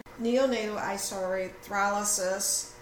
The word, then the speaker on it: neonatal isoerythrosis: Okay, proper pronunciation. Neonatal isoerythrosis. That's a tongue twister. Is that correct? – Yeah, it is.